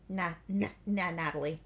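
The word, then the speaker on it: Nathalie: Natalie. – It's pronounced the same. – Is it? – Even though there's an H. It's just French, somehow.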